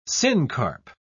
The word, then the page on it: syncarp: British English